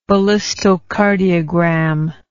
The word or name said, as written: ballistocardiogram